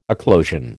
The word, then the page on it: eclosion: American English